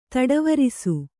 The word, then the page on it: ದಾಟುವರಸೆ: dāṭu varase